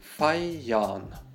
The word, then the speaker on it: feiern: Произносится там то, что мы всегда делаем с буквой «Р» в конце слова. То есть превращаем ее практически в «А». Feiern. Feiern. Старайтесь там не выходить на эту «Р».